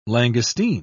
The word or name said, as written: langoustine